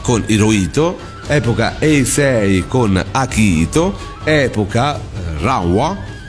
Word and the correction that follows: Reiwa: detto bene? – Reiwa. – Reiwa. Reiwa.